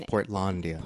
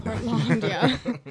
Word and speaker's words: Portlandia: Yeah, I just finished season one of Portlandia, and that is now on... That's how you say it, right? – Yeah. – It has to be. And that's on Netflix. And it's Portlandia. – Portlandia.